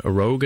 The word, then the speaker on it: Rouge: Rogue, I think he's pronounced it, but it's actually it's spelled Rouge, like the French word for red, but he said that I think his family's lost that pronunciation years ago.